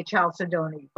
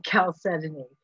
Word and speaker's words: chalcedony: And it's most people mispronounce it and say chalcedony, but it's really chalcedony. That's spelled C-H-A-L-C-E-D-O-N-Y.